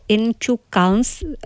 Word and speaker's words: Inčukalns: Možná, že Katka hned opraví moji výslovnost, ale řekla jsem si to slovo Inčukalns, řekla jsem to správně? – Ano, řekla jsi to správně.